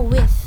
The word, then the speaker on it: with: Oke, jadi cara baca TH itu, misalnya nih ada kata with. Nah, cara bacanya itu nggak kayak with, itu nggak. Jadi yang benar, cara baca yang benar itu adalah with.